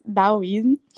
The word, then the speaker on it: taoísmo: desde quando eu ainda estava lá nos Estados Unidos, eu já estudava o daoísmo, que se escreve com T aí, para quem não conhece, é o taoísmo, mas se diz daoísmo.